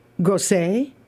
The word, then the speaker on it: Grosse Pointe: And gross has got an E on the end of it. Does that mean it's pronounced differently? – No. – Grosse.